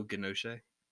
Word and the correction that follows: Giacchino: Oh, Giacchino? – Yeah, thank you.